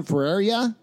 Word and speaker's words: Ferreira: All right, right back into it from Marvel Comics Spider-Man Noir number one, written by Margaret Stoll and art by Juan Ferreira, I think. – Yeah, that's how you pronounce it. Sounds right.